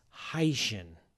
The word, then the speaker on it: Hycean: No, it's supposed to be pronounced Hycean. Hycean. Because Hycean is a portmanteau of hydrogen ocean.